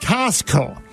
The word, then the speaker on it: Costco: go visit Eddie Olczyk noon today at Costco. Did you ever notice no one pronounces the T? It's Costco. – No, right? – Nobody does. – C-O-S-T-C-O.